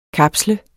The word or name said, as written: kapsle